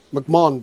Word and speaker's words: McMahon: McMahon, denk ek, is die korrekte uitspraak. – Correct.